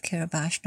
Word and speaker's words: Kiribati: I'm using the local pronunciation of Kiribati, not Kiribati.